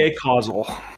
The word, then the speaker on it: Acausal: Iapetus the Acasual, that would be Iapetus the Acausal. – Sure. – As in Iapetus the either has no cause or behaves in ways that have no cause or is related to having no cause.